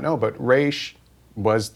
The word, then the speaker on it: Ra's al Ghul: And you did Ra's al Ghul or Ra's al Ghul. There's two schools of thought about how you say his name. – There's such a debate. But you say Ra's. – I say Ra's.